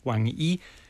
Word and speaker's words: Wang Yi: So he's been talking with Wang Yi.